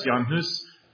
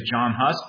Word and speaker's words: Jan Hus: Jan Hus, which some people say is John Hus, but he's not English. He's Czech. And so we're going to pronounce his name like he would pronounce his name.